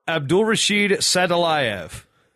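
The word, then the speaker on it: Abdulrashid Sadulaev: Sulov? – Abdulrashid Sadilayev.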